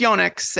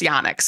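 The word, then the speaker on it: Yonex: In Japan, it's Yonix. And on Long Island, it's Yonix. So that's part of why I pronounce it the way I do.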